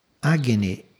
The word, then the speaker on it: Agni: Agni é o correto para dizer este fogo.